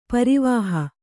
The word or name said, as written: ಪರಿವಹ